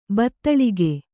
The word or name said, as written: ಬತ್ತಳಿಗೆ